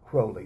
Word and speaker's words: Crowley: It is pronounced Crowley, or Crowley told people to pronounce it Crowley. It rhymes with holy.